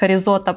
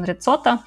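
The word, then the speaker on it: Rizzotto: Я его э, произносила как Ризота, потом оказалось, что он Рицота.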